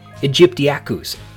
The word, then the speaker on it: aegyptiacus: All my life, as a courtesy to Jurassic Park 3, I've been mispronouncing and misreading Spinosaurus aegyptiacus. It wasn't until terrific guest from episode 56 Jingmei O'Connor was talking about the new mount at the Field Museum when she pronounced it aegyptiacus, and also correctly, that I noticed how it's actually said.